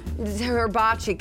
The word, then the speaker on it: hibachi: Hibachi.